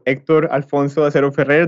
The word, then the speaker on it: Hector Alfonso Acero Ferrer: I'm Hector, so Hector Alfonso Acero Ferrer, that's my full name. Now that I'm speaking about Colombia, I feel I should say my full name as it's supposed to be pronounced.